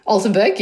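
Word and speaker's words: Altenberg: I don't know the pronunciation. 2013, Altenburg? – Altenburg, yeah, close.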